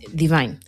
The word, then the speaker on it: Dvyn: Ella es Divine. Yo lo he dicho así, pero se escribe D-V-Y-N.